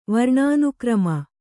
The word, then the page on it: ವರ್ಣಾನುಕ್ರಮ: Kannada